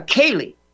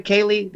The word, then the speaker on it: ceili: I introduced this concept here, Paul, in the show of a Kaylee, C-E-I-L-I. This sounds a little obscure, but you know what? It's who we are. A Kaylee, pronounced K-A-Y-L-E-E. The Scots call it a Kaylee.